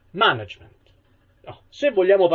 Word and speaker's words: management: Come ne sbaglia un'altra, che è management. Dove saremmo nello sdrucciolo, invece noi lo pronunciamo piano.